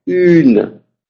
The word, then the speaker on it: une: Y no tengo que pronunciar la E final, porque en francés la E final no se pronuncia. ¿Ya? Entonces diré une.